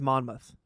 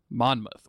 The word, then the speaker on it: Monmouth: It's Geoffrey of Monmouth. – Monmouth.